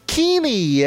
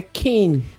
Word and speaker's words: Keane: E dessa vez estamos aí para falar de um álbum da banda Kinney. É assim que se pronuncia? Kinney. É Kin, mas é. Kin. Não tem o I no Kin. Kin.